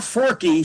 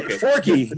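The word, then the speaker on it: Forke: Farrah Forky. She actually stresses that she pronounces it that way. Forky.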